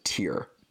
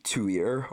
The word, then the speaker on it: Tyr: T-Y-R. I've heard them pronounce it more like Tuir, or like Tyr, or, you know, Tyre, or I don't know. I think it's Tyr. I'm just going to pronounce it Tyr.